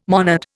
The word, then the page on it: monad: Indian